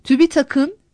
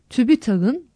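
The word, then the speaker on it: TÜBİTAK'ın: Tonsuz, sert ünsüzle biten kısaltmalar, ek aldıkları zaman okunuşta tonsuz ses tonlulaştırılmaz. Agik'in, agiğin değil. Cumuk'un, cumuğun değil. Rütüke, rütüe değil. Tübitak'ın, tübitağın değil.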